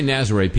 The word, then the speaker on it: Nazareth: And when you say Nazaré, people might not get an image of how that's... it's spelled like Nazareth, that's right. – Like the band Nazareth, but musically very different.